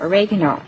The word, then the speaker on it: oregano: And this is an Italian herb, oregano, or as some people pronounce it, oregano.